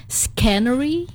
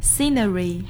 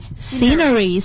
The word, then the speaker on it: scenery: Maybe like scenery. Is it right? – Oh no, it's scenery. – Scenery. So the right to pronounce is scenery.